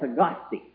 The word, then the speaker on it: Tagaste: He was born in a little town in North Africa called Tagaste, T-A-G-A-S-T-E. I believe sometimes they put an H after the first T, but while they don't pronounce it, it isn't really particularly important which way we spell it. T-A-G-A-S-T-E.